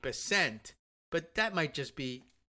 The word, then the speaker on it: Bessent: Do you pronounce it Bessent like it rhymes with pissant? – That's not a bad way of doing it or thinking about it.